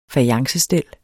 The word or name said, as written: fajance